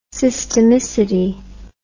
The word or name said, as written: systemicity